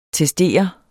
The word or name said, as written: testere